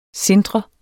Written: SINTRE

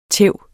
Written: tæv

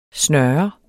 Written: snøre

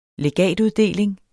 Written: legatuddeling

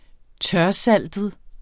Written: tørsaltet

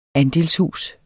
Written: andelshus

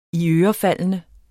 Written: iørefaldende

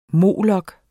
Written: molok

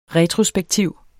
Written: retrospektiv

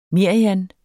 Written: merian

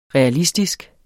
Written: realistisk

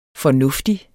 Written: fornuftig

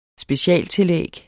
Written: specialtillæg

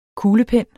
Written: kuglepen

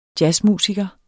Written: jazzmusiker

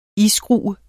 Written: iskrue

